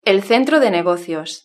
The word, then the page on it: el centro de negocios: European Spanish